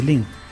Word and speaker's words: Lean: Lean. No es una pronunciación, igual estamos liando, ¿eh? La E es I y la A, I. David Lean, ¿no? – David Lean.